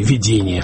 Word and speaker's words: видение: в какой-то мере это такое негативное видение будущего или видение, в данном случае, может быть, лучше ударение сделать на другой слог.